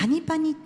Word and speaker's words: FANY: の方はですね 15 時スタートになっております、はい、はい、そしてですね 11 月 26 日はですねなんとなんと調整軍はい、はい、パニパニっていうパニパニですね。はい、 FANY、 FANY って書いてパニパニって読